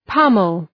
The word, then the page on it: pommel: ‘pʌməl